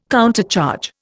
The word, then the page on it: countercharge: Indian